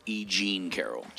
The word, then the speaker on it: E. Jean Carroll: Right, and it's my understanding, it's not Jeanine Carroll, it's E. Jean Carroll. Emphasis on the wrong syllable.